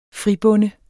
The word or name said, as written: fribonde